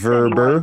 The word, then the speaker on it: VRBO: It's Verber. Anyway, it's pronounced Verber.